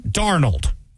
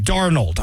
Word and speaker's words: Darnold: And that was back when we found out they were for sale, especially when we found out that their quarterback, Darnold, was actually having... – Sorry, what's his name? – Darnold. Got to say that right. Darnold. I don't know why I go the other weird way with it.